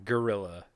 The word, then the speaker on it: guerrilla: while you were actually referencing G U E R, guerrilla. – It, it's uh, pronounced wait, the same.